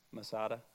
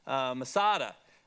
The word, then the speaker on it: Masada: And it is believed the reference to the stronghold is Masada, okay, right here. I assume I'm pronouncing that properly. – Masada, okay. Masada, that is believed to be the stronghold.